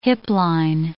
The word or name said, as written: hipline